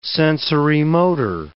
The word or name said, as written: sensorimotor